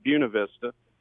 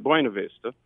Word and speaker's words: Buena Vista: If you're from there, you pronounce it Buena Vista. And if you're not from there, the whole rest of the world pronounces it Buena Vista.